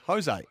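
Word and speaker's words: José: Sam, by the way, Jose is pronounced Jose. It's Spanish. And Jay is pronounced as an H.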